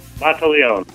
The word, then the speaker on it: Monteleone: Monteleone. – All right, there you go. So the E at the end is silent.